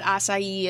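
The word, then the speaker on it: açaí: I mean, I don't know what acai is.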